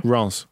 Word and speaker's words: Reims: And we can say that Ranza a good side. – How did you pronounce it? – Reims. – Reims.